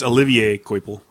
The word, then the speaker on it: Olivier: It's Olivier Coipel. – Dude, it's Oliver and Company. Whatever. – There's an extra I in there you're not pronouncing.